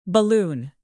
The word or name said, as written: balloon